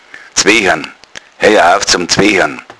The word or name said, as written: zwechern